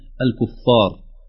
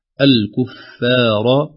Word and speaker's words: الكفار: الكفار يجري على لسان الناس في العامية الكفار، يفخمون الفاء بمدها، لكن في الفصيحة الكفار، إذن بترقيق الفاء بالمد بعدها لئلا تشتبه بنطق العامية.